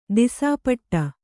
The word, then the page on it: ದಿಸಾಪಟ್ಟ: disā paṭṭa